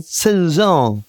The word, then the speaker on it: Zhejiang: Also eine ärgerliche Frau hat in der chinesischen Provinz Zhejiang oder Zhejiang, Zhejiang. – Genau so wird's ausgesprochen. – Genau, so wird es ausgesprochen.